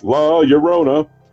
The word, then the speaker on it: La Llorona: In Latin American folklore, specifically Mexican, La Llorona, pronounced La Llorona, the weeping woman or the crier, is one of the most famous oral legends.